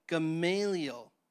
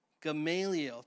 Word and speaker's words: Gamaliel: And the proper pronunciation is Gamaliel. Gamaliel.